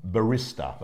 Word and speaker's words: barista: Tree, water, planes, this, that, barristers, coffee. That's the process. – We spoke about that the other week. Barista. – The barista. – It's spelled barrister, but yeah. It's barista.